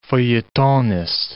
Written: feuilletonist

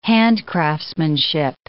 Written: handcraftsmanship

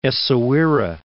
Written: Essaouira

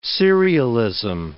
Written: serialism

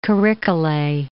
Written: Kirikkale

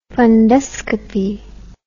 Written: funduscopy

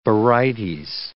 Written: barytes

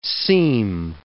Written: seme

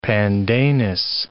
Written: pandanus

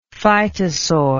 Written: phytosaur

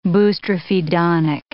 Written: boustrophedonic

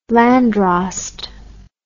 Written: landdrost

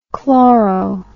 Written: chloro

